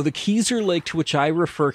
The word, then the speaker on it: Kezar: a lake in New Hampshire by the name of Keezer. That's spelled K-E-Z-A-R for those inclined to Google it.